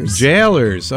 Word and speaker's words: Gaolers: How do you say that? – Jailers. – Okay. – Yeah. It's the old British way to say jailer, like the prison guard.